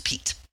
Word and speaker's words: Piet: But his first name is Pete, even though it's spelled P-I-E-T, it's Pete.